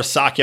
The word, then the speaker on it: Psaki: And Jen Psaki, I'm just going to call her Psaki. I'm not going to pronounce the P like a pterodactyl.